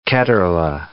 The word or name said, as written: Ketterle